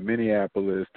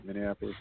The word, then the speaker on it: Minneapolis: to New York to Minneapolis to anywhere. Like, am I even saying that right? Minneapolis? Did I say it right? – Yeah, but you are.